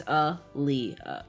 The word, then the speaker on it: Aaliyah: Yeah, oh, because oh, Aaliyah's name is spelled A A L I Y A H, which I, you know, and so it's a Lee, yeah.